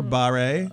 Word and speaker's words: Guillain-Barré: So, does anybody, first of all, know how it's correctly pronounced? – I worked in print. Sorry, Guillain-Barré. – Or Barre. I've also heard. – Yeah. I work in radio. I'm sorry. – You should know that. – It's Guillain-Barré.